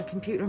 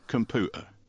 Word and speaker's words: computer: The T should not be pronounced like a D, but as a glottal stop, and the Y sound should not be in the syllable pew, so it should be computer.